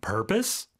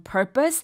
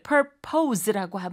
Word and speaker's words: purpose: 자, 이거 purpose라고는 발음하지 않습니다. P-O-S-E로 끝나긴 하지만 비록 이걸 purpose라고 하면 안 되고요. 우리가 propose 할 적에는 O's라고 하는데 purpose 같은 경우는 강세가 앞에 오기 때문에 어떻게 할까요? – Purpose. – Purpose. – Us, purpose. – Purpose, 그렇죠. – Purpose. – O 발음이 아니라 으 발음으로 purpose라고 하게 됩니다.